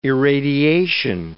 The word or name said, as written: irradiation